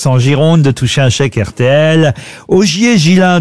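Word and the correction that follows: Ghiselin: de Busbeck, pardon, j'ai écorché son nom. – Il me semblait bien aussi. – Ça, c'est chou. Ça, oui. Est-ce que vous pouvez appeler Busbeck? – Busbeck. Ogier Gislin de Busbeck. – Ah, Gislin.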